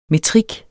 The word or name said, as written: metrik